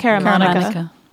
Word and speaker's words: Caramanica: I don't know what John Caramanica, is that how you pronounce it? – I think it's Caramanica.